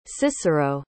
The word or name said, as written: cicero